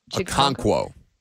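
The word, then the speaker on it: Chig Okonkwo: You know, I think over the last few weeks, you've seen a couple of those young pass catchers, Treylon Burks and Chigakonkwo. I'm saying his name right? – Chigakonkwo.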